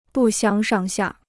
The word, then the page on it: 不相上下: bù xiāng shàng xià